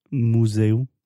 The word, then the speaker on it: museum: Can you say the word museum for me. Museum? – Museum. – Museum. – Yeah. So there is that kind of my sound at the beginning, like me and you, mu.